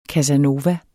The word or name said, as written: casanova